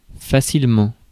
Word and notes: facilement: France; [fa.sil.mɑ̃]

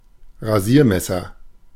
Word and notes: Rasiermesser: /ʁaˈziːɐˌmɛsɐ/

